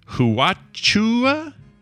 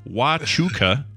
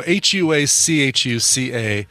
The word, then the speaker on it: Huachuca: He says, Huachua, anyway, is pronounced Huachuca. So I'm saying it the way I would say it. Now he's saying it the way he would. – The Huachuca. Correct way, yeah.